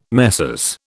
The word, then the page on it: Messrs: British English